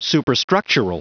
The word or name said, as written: superstructural